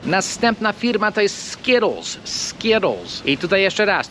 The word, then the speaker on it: Skittles: I po amerykańsku, jak mamy T po środku słowa, bardzo często, albo prawie zawsze zmieniamy to T na D. Dlatego Amerykanin to wymówi Skittles. Skittles. Ktoś w Anglii będzie wymawiać to T, z tego co rozumiem, i może powie na przykład Skittles, albo coś w tym stylu.